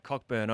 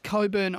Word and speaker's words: Cockburn: Cockburn Oval. – No, it's pronounced Coburn Oval.